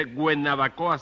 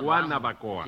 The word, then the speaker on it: Guanabacoa: Yo, porque no se dice Guenabacoa, sino Guanabacoa.